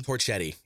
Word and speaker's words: Torchetti: What did you, how'd you say his name? Torchetti? – Torchetti. Yeah.